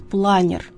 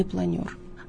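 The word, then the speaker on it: планёр: Я не могу считать культурным человеком того, кто говорит «планер», а не «планер».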